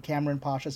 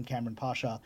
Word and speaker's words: Kamran Pasha: You can, if you just want to follow my adventurous life, you can follow me on Instagram, which is my name, Cameron Pasha 72, and I'll spell that.